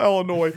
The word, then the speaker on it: Illinois: For those of us that say it correctly, it's Illinois. – What is it? – Illinois. – Illinois? Yeah. – Ill. Not L. – Illinois? – Just like it's milk.